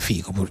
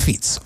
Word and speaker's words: Fico: non si chiama Fico, cioè si scrive Fico ma si legga Fico, e che però adesso si trova nella condizione di dover trovare degli alleati.